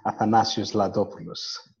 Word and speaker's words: Athanasios Ladopoulos: Just to make your task a bit more helpful, Athanasios Ladopoulos.